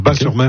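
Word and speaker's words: Batz-sur-Mer: Moi, on prononce Bas. Le T ne se prononce pas. Bas-sur-Mer.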